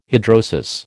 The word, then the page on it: hidrosis: American English